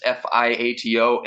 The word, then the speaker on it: Fiato: Chris Fiato, Odd Combo at YouTube, and that's Fiato. And yo, kudos to you. You pronounced my last name right for some reason. A lot of people have a hard time with the Fiato.